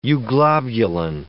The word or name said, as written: euglobulin